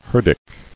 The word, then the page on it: Herdic: her·dic